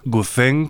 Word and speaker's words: Guzheng: Mei Han es una artista china, intérprete de Zhen, también llamado Guzheng, Guzheng, tal y como se escribe con Z y H, un instrumento emparentado, por ejemplo, con el koto japonés, quizá más conocido.